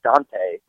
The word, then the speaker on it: Deontay: So it's all in there, but yes, Deontay Burnett will, I think his first name is actually pronounced Dante, but that's how they pronounced it.